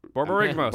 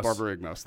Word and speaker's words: Borborygmos: Borborygmos. – Borborygmos, thank you.